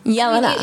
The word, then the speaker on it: Jelena: So that would be like Yelena. The right pronunciation.